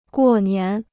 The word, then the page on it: 过年: guò nián